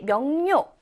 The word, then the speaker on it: 명료: 자, 우리 명료, 이거를 어떻게 읽냐면, 명료라고 소리 내어서 읽어야 해요. 명료! 막 이렇게 읽지 않고요. 명료하다, 명료하다. 편하게 발음을 하다 보니까 이 니은처럼 소리가 나게 되어 있습니다.